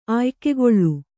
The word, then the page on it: ಆಯ್ಕೆಗೊಳ್ಳು: Kannada